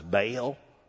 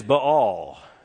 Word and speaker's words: Baal: There, and the proper name of this, if you grew up in a Southern Baptist Sunday school like I did, you heard this idol mispronounced as Baal. Don't go following Baal. It's a diphthong, dear friends. It is Baal.